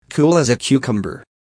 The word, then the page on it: cool as a cucumber: American English